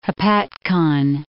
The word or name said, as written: Hopatcong, Lake